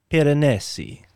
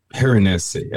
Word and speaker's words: Piranesi: Can I do the pronunciations? – Yes, sir. Yes, go for it. I'm probably butchering that That's one. That's all right. So before it was Schaffhausen, and then now we're Giovanni Battista Piranesi. – Piranesi. Okay. Thank you.